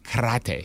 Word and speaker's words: karate: Mystery person, you said the word karate wrong. It's karate. – You have to roll the R.